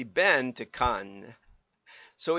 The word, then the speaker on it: Cannes: I did actually listen to about a dozen of these, and it's pronounced by the French. Cannes. With a little E at the end, but very soft. Cannes, like that. That's the right way. But cons is the way English people say it. So go for it. Either way you like.